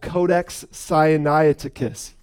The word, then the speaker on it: Codex Sinaiticus: Codex Sinaiticus. Yes, I said it right, so I sound smart.